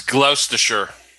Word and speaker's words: Gloucestershire: Number four, we have the LHG Brew Pub in Bristol, Gloucestershire, England. I'm sure I butchered that. – That sounds like a sauce. It's Gloucestershire.